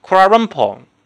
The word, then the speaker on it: Kuala Lumpur: 包括吉隆坡，吉隆坡是中国的说法，它的标准发音叫 Kuala Lumpur，对吧？这个很很一读就知道是这种啊东南亚的原生语言的一种味道。